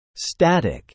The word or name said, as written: static